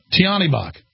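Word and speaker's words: Tyahnybok: Oleh, how do you say it again? Tiambach? Tiambach. Oleh Tiambach.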